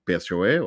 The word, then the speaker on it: PSOE: 9 ou 10 eram controladas pelo PSOE ou PSOE, dependendo da dicção que queiram dar, da pronúncia que queiram dar ao partido, do primeiro-ministro Sanchez, Pedro Sanchez.